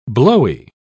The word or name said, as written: blowy